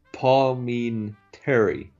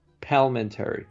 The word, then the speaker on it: Palminteri: And although we want to get this guy's name right, because we keep butchering it. It's Chaz Palminteri. Palminteri. – Yeah. – Palminteri. – Sound it out.